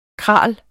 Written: kraal

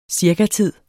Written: cirkatid

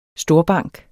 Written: storbank

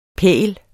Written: pægl

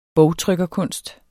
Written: bogtrykkerkunst

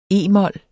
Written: e-mol

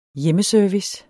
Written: hjemmeservice

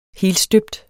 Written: helstøbt

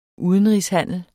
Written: udenrigshandel

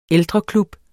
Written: ældreklub

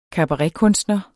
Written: kabaretkunstner

